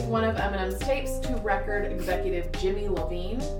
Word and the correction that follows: Jimmy Iovine: I should make sure that's how you say his name. How do you? I'm not sure. How do you say his name? I feel like I should know this. Here we go. Let's see if this is. – Jimmy Iovine. – No wonder it didn't sound right.